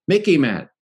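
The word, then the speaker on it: MICIMATT: Mickey Mat. It rhymes with Mickey Mouse, sort of, all right?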